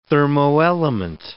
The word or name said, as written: thermoelement